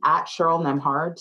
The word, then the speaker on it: Nembhard: My name, @CherylNembhard, Cheryl. Nembb is silent, y'all. Hard.